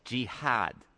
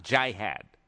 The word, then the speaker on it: jihad: But it's jihad, not jihad.